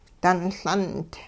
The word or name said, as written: dunLundi